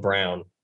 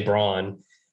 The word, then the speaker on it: Brown: It still drives me insane that he pronounces his last name Brown. Cause I want to say Braun.